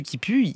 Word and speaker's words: Kipu: Et Monsieur Qui Pue, alors ça s'écrit K-I-P-U, mais enfin, c'est Monsieur Qui Pue, c'est un clodo, Monsieur Qui Pue.